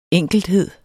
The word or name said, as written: enkelthed